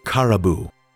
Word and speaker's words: Caraboo: With a little more coaxing and gesturing, she got the girl to share her name, which she pronounced as Caraboo.